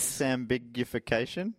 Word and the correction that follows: disambiguation: I've never said that word out loud before. – Sambiguation. – This is what I added a syllable. – Disambiguation.